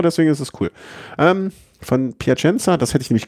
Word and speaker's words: Piacenza: Von Plaisant, nee, wie spricht man das aus? – Piacenza. – Aber dann ist es falsch geschrieben.